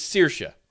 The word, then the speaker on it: Saoirse: Her name is Saoirse.